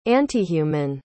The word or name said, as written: antihuman